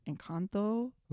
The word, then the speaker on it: Encanto: I don't say it right. Encanto. – That was good.